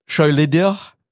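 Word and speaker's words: Söyledir: Wir hören in diesem Stück, dieses Stück heißt, das steht auch sogar, wie man das aussprechen soll, Schöleder, so heißt das Stück, das heißt, gesprochen ist S, oder geschrieben ist S-O-Umlaut Y-L-E-D-I-R aus dem Jahr 1983.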